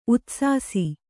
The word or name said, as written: ಉದಾಸಿಸು